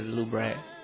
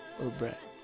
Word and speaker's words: Louvre: Bueno, yo solo manejo inglés, pero sería Museo de Louvre. Louvre. Así como Pepe Le Pew, Louvre.